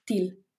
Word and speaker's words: Teal: Esa es un poco la idea de TIL, que se escribe T-E-A-L, TIL.